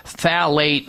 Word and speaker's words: phthalate: So just assume that you're coming in contact with these phthalates, and unless your shampoo says phthalate free or organic only on it, and phthalate, by the way, is spelled with a P, P-H T-H-A-L-A-T-E.